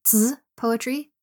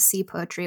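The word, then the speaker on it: zi poetry (词): In terms of poetry, more specifically, she wrote what's called song lyric or zi poetry. It's spelled C-I. So you might hear it referred to as C poetry, but it's pronounced zi poetry, I think.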